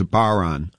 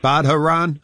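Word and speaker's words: bodhrán: the Irish drum is a baron, and it's pronounced, or it's spelled B-O-D-H-R-A-N. So people are always saying, you playing that Badharan?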